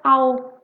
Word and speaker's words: I'll: Esse é fácil, porque o I will, você muda um pouco e fica I'll. Au, como se fosse um AL, é só você respeitar o L, tá?